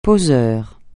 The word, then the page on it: poseur: francais